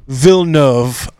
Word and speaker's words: Villeneuve: Now, The Arrival, isn't that a Villanueva? – Actually, yeah, it is. – That's why another you liked it. – Denis Villeneuve. That's how I decided to pronounce it.